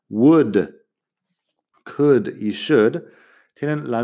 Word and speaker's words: would: Pero claro, would, que es como una forma condicional, un verbo auxiliar, un modal, pongamos, se pronuncia igual que wood de madera o bosque, que es W o O D. Pero wood, el verbo auxiliar, tiene la L que no suena.